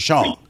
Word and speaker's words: Rushion: And so, you know, people call the show, they say Russian. And I kind of let it slide sometimes because I just don't want to just blow them out the water. Go, hey, my name is Rashawn.